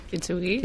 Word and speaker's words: Kintsugi: como ya lo pronunciáis vosotros, como se pronuncia. – Kintsugi, sí. Tal cual, vale.